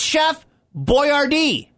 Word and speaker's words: Boyardee: It's Chef Boyardee.